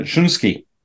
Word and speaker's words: Shunsuke: Shunsuke.